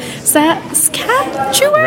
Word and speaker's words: Saskatchewan: Saskatchewan? – Right.